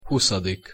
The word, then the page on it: huszadik: Hungarian